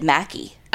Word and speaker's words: McKay: Okay, so I have some family, I guess, I think, on my grandma's side, but it's spelled McKay, but they pronounce it Mackey.